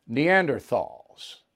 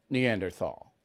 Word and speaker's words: Neanderthal: And he mispronounced Neanderthal, which I did too. It's Neanderthal. I didn't know that, but I don't care.